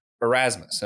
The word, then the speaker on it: Erasmus: You were on the Partially Examined Life Philosophy podcast, and you were talking about the Dutch philosopher Erasmus. Am I saying that correctly? – Yep, Desiderius Erasmus.